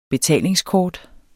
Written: BETALINGSKORT

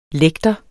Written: lægter

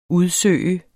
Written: udsøge

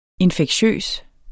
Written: infektiøs

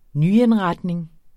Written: nyindretning

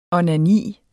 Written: onani

